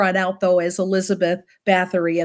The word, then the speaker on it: Báthory: So we would pronounce it Báthory. It's actually Bator. They don't pronounce the Y.